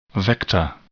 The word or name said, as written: vector